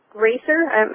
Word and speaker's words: RACR: Construction activities were complete about 10 years ago, but there is no PCOR or RACER. I'm pronouncing the acronym, but it's RACR.